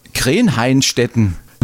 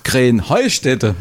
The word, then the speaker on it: Krähenheustätte: Ja, ihr saget immer Krähenhainstetten. Und dabei heißt es doch Krähenheustätte, oder wie es? – Krähenheustätte. – Ja, genau, und das die Betonung auf dem Heu.